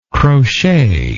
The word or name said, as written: crochet